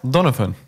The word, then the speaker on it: Donovan: Hoe spreek je het uit? – Donovan. Donovan? Ja. Oké.